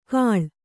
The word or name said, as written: ಕಾಳ್